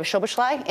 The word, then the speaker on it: Szoboszlai: I've been very encouraged by the play of Szoboszlai in the midfield.